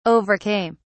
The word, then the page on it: overcame: British English